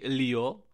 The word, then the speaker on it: Lillo: Takže místo něho musel přijít Juanma Lillo, španělsky Lio, ale fakt se to strašně divně vyslovuje, takže budem říkat asi Lillo, já nevím.